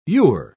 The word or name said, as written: you're